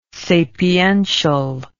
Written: sapiential

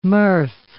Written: mirth

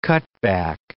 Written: cutback

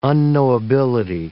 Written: unknowability

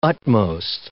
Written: utmost

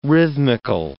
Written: rhythmical

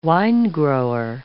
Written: winegrower

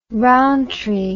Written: Rowntree

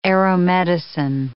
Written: aeromedicine

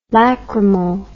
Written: lacrymal

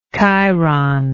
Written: chiron

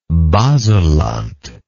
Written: baselland

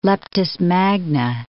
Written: Leptis Magna

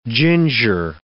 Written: ginger

